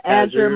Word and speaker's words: Azure: Microsoft, is it Azure? People pronounce it different ways, but Azure.